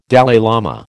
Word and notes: Dalai lama: American English

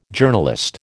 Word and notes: Journalist: American English

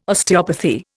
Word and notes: Osteopathy: Indian

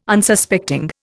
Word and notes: Unsuspecting: Indian

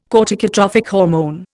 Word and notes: corticotrophic hormone: Indian

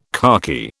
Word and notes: khaki: British English

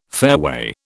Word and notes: fairway: British English

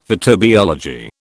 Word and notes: photobiology: British English

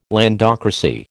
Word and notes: landocracy: American English

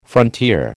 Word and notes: frontier: American English

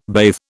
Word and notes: bathe: British English

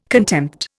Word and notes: contempt: Indian

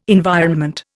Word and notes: environment: Indian

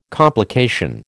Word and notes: complication: American English

